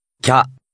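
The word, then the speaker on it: きゃ: This is to indicate a contracted syllable, meaning the two syllables are read as one quick contracted syllable. Listen and repeat number 18. – Kya.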